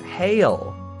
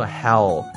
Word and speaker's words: hail: Hail Hail H-A-I-L H-A-I-L 发音要记住哦它是一个双元音加上一个 O 念作 hail hail 不念作 hell 那是地狱的意思啊